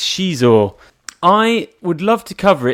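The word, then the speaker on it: Xizor: I think I never said his name right. It's She-Saw. – Okay, I always used to say Prince Zizor.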